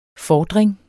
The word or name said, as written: FORDRINGERNE